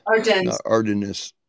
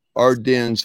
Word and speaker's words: Ardennes: And that's pronounced Ardennes. – Ardennes. – Ardennes Forest.